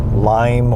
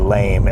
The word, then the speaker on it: Leim: It's Joshua Leim or Lame, L-E-I-M.